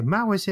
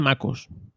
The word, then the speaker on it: macOS: Una pregunta primero, ¿cómo se dice? ¿Mac OS X o Mac? – Ahora se dice MacOS. Sí. – MacOS, vale.